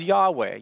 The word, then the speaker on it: Yahweh: I am who I am is a Hebrew word that we pronounce as Yahweh.